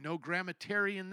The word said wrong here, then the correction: grammarian: there. Grammarian. There we go.